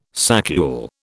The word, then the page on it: saccule: British English